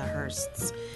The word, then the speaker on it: Hursts: And our name is spelled H-U-R-S-T. With an S at the end for the Hursts.